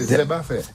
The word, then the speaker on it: Zeb à fait: Zeb, comme herbe, à fait. – Zeb. – Parce qu'il est chargé en fer aussi. – Ah, Zeb à faire. – Ouais. On prononce pas les R ici.